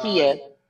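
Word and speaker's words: Ma'Khia: I did a show on the Makia Bryant. If I'm saying her name wrong, to the families that see this, I apologize. – It's Makia.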